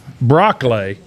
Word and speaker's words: Broccoli: Broccoli. – Like broccoli? – Yeah, like broccoli.